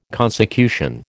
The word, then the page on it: consecution: American English